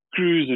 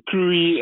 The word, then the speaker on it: Kruis: I keep saying Kruis, and I remember you saying to me when I asked you how you pronounced your surname, it's Kruis. It rhymes with grace.